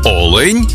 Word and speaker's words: олень: в однині і в множині наголос падає на перший склад – олень та олені.